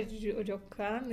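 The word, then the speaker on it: Рёкан (旅館): А что такое рёкан или рёкан, я не знаю, да-да-да. – Как читается? Как правильно? – Рёкан, да.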